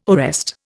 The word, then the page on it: arrest: Indian